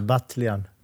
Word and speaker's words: Batljan: Nej, nu sa jag faktiskt fel på efternamnet. Hur skulle jag uttala efternamnet, Ilja? Batljan. Batljan.